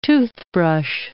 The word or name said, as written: toothbrush